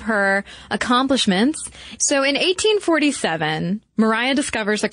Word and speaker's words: Maria: Yes, it is spelled to look like Maria, M-A-R-I-A, but it is pronounced Mariah.